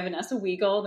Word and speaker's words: Vanessa Wiegel: That is the correct German pronunciation. I also go by Vanessa Wiegel, the more Americanized version of saying that.